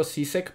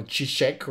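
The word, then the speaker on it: Žižek: Yo siempre le digo Sisek porque me choca, pero es como chisek o shishish.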